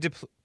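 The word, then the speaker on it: Des Plaines: Am I pronouncing that right? – DePlain? Yes, you are, sir.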